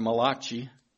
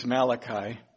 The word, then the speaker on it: Malachi: Or as some pronounce the word Malachi. But I think it's Malachi.